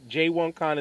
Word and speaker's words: J1Con: J1Con that's going down in Jamaica next year. Yeah, I said that right. J1Con is going to be in Jamaica next year.